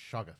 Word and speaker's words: Shoggoth: S-H-O-G-G-O-T. – Skywalker. – Shoggoth. a disembodied voice is telling me it's pronounced Shoggoth.